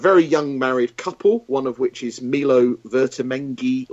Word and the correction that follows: Ventimiglia: Milo Ventimiglia.